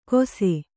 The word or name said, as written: ಕೊಸೆ